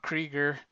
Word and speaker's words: Krieger: It's just at Rob Krieger. Just sounds like Seeger, but not spelled like Seeger.